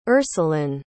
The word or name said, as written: ursuline